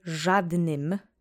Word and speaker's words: żadnym: The accent is on the first syllable. Let's say żadnym together three times slowly.